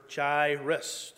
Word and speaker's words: Jairus: And if I make a mistake and called him Jairus instead, because initially, until I looked up how to pronounce it, I was calling him Jairus. And then I looked it up and said, oh, it's Jairus.